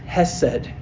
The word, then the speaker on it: hesed: Because in the Hebrew, that word means, it's pronounced hesed. Hesed. Could you repeat that word with me? Hesed.